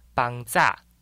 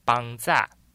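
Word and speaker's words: Pangcah: 阿美族的族语发音叫做阿密斯，阿密斯，或是有另外一种发音叫做邦扎，邦扎。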